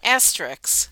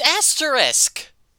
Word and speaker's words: asterisk: Asterix. – Asterix. – It's asterisk. – Asterix. – Asterix. – No, it's SK, not KS.